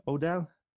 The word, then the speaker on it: O'Dowd: Mr. Dan O'Dowd. Is that how you pronounce his name, you think? O'Dowd? – Yeah. Sounds like.